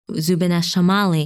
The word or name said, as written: Zubeneschamali